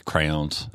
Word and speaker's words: crayons: Crayons. I practiced that for a while. It's like crowns, crayons, crowns.